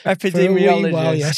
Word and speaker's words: epidemiologist: Epidemiologist.